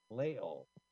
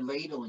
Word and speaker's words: Lael: You can find her on Twitter at her name, which is spelled L-A-E-L-G-O-L-D. Lael. – I was calling her Ladle, and she didn't even notice.